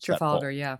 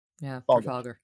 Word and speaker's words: Trafalgar: and he doesn't include the is it pronounced Trafalgar? How is it that pronounced? – Trafalgar, Trafalgar.